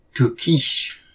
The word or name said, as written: Tohkish!